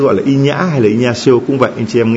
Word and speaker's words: Ignacio: Vì phát âm mà phiên âm tiếng Việt đọc là Thánh Inhã, còn nếu mà đọc nguyên tên của ngài gọi là Ignacio.